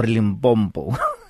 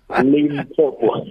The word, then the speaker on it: Limpopo: I think it's Limpopo, not Limpompo.